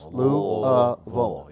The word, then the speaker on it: Louisville: I was taught Louisville. Three syllables. Louisville.